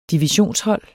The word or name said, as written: divisionshold